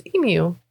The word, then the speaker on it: emu: Most people would say emu. – It's emu. – Right. – Yeah. – Australians get upset when people don't pronounce the U as a U and then we pronounce it as a oo instead.